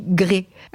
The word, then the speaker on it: grès: Alors, ça s'écrit G-R-E accent grave S. Et on dit grès.